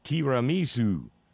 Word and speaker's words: tiramisu: I've just been provided with the correct pronunciation in the Italian of tiramisu. Flavio tells me in Italian, repeat after me, tiramisu, my favorite dessert, Brother Flavio says.